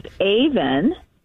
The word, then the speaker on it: E'van: And my middle name is Avan, and that's spelled E'VAN.